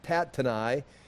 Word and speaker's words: Tattenai: Okay, at that time, uh, Tattenai, Tattenai, I've got to pronounce Tat, Tattenai. Uh, I looked it up so I could pronounce it correctly.